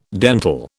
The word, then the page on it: dental: British English